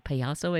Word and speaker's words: Payaso: Actually, let's mention Phatty and Payaso, right? Payaso, I said it correctly. – Yeah. That's perfect.